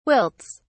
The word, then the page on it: wilts: British English